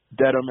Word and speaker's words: Dedham: But we also took a detour outside of Boston City proper and we went to the town of Dedham. Is that how you say it? – Yep, Dedham, Massachusetts.